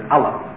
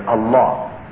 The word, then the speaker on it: Allah: Most non-Muslims, Westerners or Easterners, are familiar with the idea that Muslims call their God Allah, or it's usually pronounced in the West as Allah. We pronounce it actually as Allah.